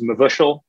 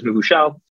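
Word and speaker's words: mevushal: One word that we're going to talk about today is mavushal. In Hebrew, it literally means cooked or been cooked. And if you hear me pronouncing it differently, the, the Yiddish is mavushal, the Hebrew is mavushal.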